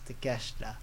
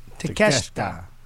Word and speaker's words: Takeshita: Konosuke Takeshita. – Takeshita.